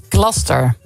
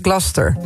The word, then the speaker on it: Gloucester: De Engelse plaatsnaam Gloucester spreek je uit als... – Gloucester. – Gloucester. Ja. Als één vloeiend woord. Gloucester.